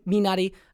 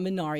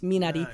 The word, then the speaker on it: Minari: Asian Americans with Steven Yeun and him starring in Minari. That's the pronunciation. It's not Minari. It's Minari.